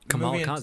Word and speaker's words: Kamala Khan: Kamala Khan, is that her name? Did I say her name right? – Yeah, you did, yeah.